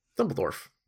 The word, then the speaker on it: Dumbledore: Yeah. Dumbledore. That is the correct way to say Dumbledore.